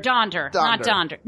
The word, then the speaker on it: Donder: Dander was the answer to that. – Poor Dander. – No, Donder. Not Dander.